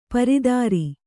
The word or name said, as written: ಪರಿದರ